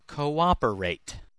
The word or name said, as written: cooperate